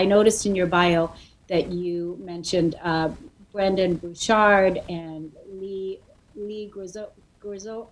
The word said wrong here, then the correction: Graziosi: Dean Graziosi.